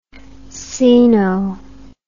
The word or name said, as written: ceno